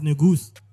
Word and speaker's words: Negus: Yeah, it's not Negus. – No. – Because my producer's name is and he says it's Negus. – It's a Merrick for King of Kings. – Yeah. He says it's pronounced Negus, as in with a goose. Ne.